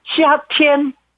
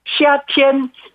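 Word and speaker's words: 시아티엔 (夏天): 그 일성, 둘 다 일성, 일성입니다. 그래서 발음은 시아티엔, 시아티엔.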